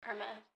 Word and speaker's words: Hermes: So I believe I'm saying it correctly. – Hermes. – Okay, cool. There we go.